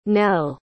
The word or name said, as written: nell